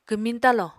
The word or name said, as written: kwmiñ talo